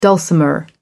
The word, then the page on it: dulcimer: American English